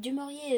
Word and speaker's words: du Maurier: You pronounce it like that? – Well, Daphne du Maurier? – Yeah. – But she's not French. – But du Maurier is very French. – It is definitely French.